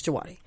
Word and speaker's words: Djawadi: Is it Djawadi? I think it's Djawadi. Ramin Djawadi. – Or Djawadi. – No, it's Djawadi.